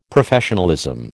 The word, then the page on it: Professionalism: American English